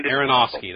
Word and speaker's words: Aronofsky: Aronofsky, that's how you say it.